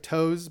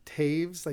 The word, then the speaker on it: Taves: And I actually think that when I was going door to door, I would be like, it looks like Toes, but it's pronounced Taves, like T-A-V-E-S.